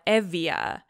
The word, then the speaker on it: Euboea: but Euboea, you know, the island, they call it Evia.